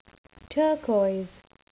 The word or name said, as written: turquoise